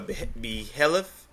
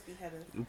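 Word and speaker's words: behelit: It's called a behelif. I'm pretty sure I butchered that name. – I think it's behelif.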